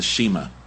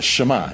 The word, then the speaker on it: Shema: It's S-H-E-M-A. Please do not pronounce it as the Shema. Okay? It's the Shema.